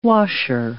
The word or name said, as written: washer